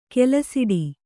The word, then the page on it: ಕೆಲಸಿಡಿ: kelasiḍi